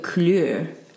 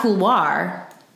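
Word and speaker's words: coulure: Couleur. That might not be how you pronounce it in French. But it's like color. – Oh! – But it's not couloir. See, it's C-O-U-L-U-R-E.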